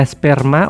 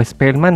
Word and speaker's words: esperma: Dejaban caer sobre el diario la esperma o espelma, no sé cómo se dice, esperma o espelma. – Esperma. – Siempre. Esperma de la vela.